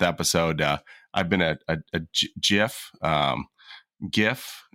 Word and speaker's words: GIF: And lastly, thank you, for pronouncing it as GIF and not JIF.